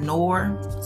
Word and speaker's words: Noire: Revisit Tales from the Hood, and then also Horror Nor, I believe that's how you pronounce it, Nor, spelled N-O-I-R-E.